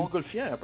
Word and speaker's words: Montgolfier: The Montgolfier brothers.